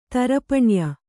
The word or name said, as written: ತರಪಣ್ಯ